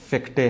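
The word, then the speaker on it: Fichte: خوب، فیختے جو تھا، یا فارسی میں اس کو فیختے جو ہے وہ کہا جاتا ہے، لیکن اصل تلفظ اس کا یوہان فیکٹے ہے.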